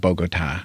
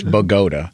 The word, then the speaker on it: Bogota: No, but I just couldn't pronounce, it's tinnitus, just like Bogota in New Jersey is not Bogota. It's Bogota.